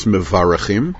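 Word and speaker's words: Mevarachim (מברכים): I just got an email from someone who said that I write Shabbat Mevorachim with an O rather than an A, and in correct Sfardit pronunciation, it should be Shabbat Mevarachin, or Mevarachim. I've answered that question in the past. I know that it's Mevarachim,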